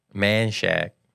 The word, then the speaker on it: Manchaca: How do you say it? – Man Shack.